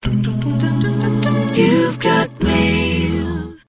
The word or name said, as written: You've Got Mail